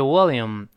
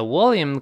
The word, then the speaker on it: William: 其实呢，这个比尔的大名呢，我在新概念第三册也讲过，应该叫 William，就是威廉，叫 William。但是 William 这个发音不好发，特别是这 L，有些美国人也觉得它别扭。 William，William 这舌尖往前指的，所以呢，昵称呢干脆就 Will，W I L L。